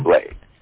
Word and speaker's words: Candomblé: Candomblé.